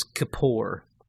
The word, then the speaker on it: kippur: The Hebrew word is kippur. K-I-P-P-U-R, pronounced Kippur.